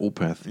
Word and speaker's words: Opeth: Es fängt, es, es, spricht sich so ähnlich aus wie Moped. – Genau, es ist aber Opeth, ja.